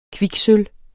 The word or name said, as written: kviksølv